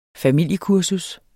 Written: familiekursus

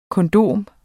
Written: kondom

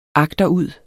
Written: agterud

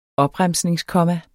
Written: opremsningskomma

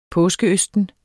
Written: påskeøsten

